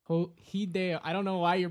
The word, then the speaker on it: Hideo: Hideo Kojima? – Yeah, is that his name? – Well, yeah, that's how you would pronounce it in Japanese. Hideo.